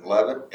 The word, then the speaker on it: Leavitt: Lauren Levitt, L-E-A-V-I-T-T.